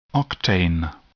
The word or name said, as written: octane